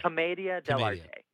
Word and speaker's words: commedia dell'arte: Commedia dell'arte.